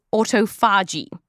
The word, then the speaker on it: autophagy: And to find out how to properly pronounce it, we track down 5 people who speak ancient Greek. That's right, there are bigger nerds than us out there. Now, they all told us slightly different things, but it tended to be something like autophagy. So that's what we'll go with.